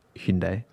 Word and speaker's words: Hyundai: I did a video about Hyundai. – That's how it's pronounced in Korean.